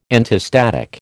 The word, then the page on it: antistatic: American English